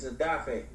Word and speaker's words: Odafe: It's Odafe, by the way. – Is Odafe? – Yeah, that's how it's pronounced. – Like, it looks like Odafe, I'm going to call him Odafe. – You know, you have to be Nigerian, it's Odafe.